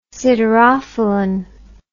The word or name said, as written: siderophilin